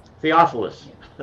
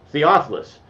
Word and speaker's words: Theophilus: In fact, he has a little article on who was who did Paul write Acts in, I can't pronounce his name, Theophilus. There you go, I got it. Theophilus.